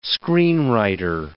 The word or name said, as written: screenwriter